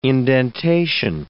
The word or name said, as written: indentation